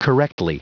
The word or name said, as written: correctly